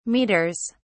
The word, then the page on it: meters: British English